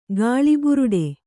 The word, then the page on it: ಗಾಳಿಬುರುಡೆ: gāḷi buruḍe